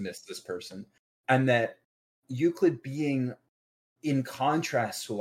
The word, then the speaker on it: Euclid: And to me, what it is that Rain and Euclid go, that's even how you pronounce it, go hand in hand. – Yeah, you said it right.